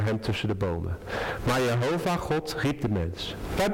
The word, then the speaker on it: Jehovah: Toen de mens en zijn vrouw, en ik ga meteen zeggen, ik ga Gods naam als Jehovah uitspreken. Sommige mensen zeggen Yahweh of Jeweh, dat maakt mij allemaal verder niet uit.